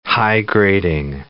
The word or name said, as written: high-grading